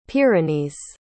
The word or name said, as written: pyrenees